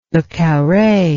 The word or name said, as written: le carre